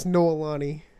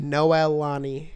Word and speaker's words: Noelani: Her name's Noelani. – Nolani? – Noelani. – Noelani. – Wow. It's spelled like Noel, A-N-I.